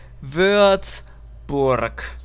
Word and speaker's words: Würzburg: Pues no, es Würzburg y es pegado. Würzburg se escribe W U con dos punticos, o sea, con la diéresis, R, Z, B, U, R, G, Würzburg. Repeat after me, Würzburg.